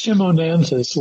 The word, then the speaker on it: Chimonanthus: Well, it's Chimonanthus, like chimney chim.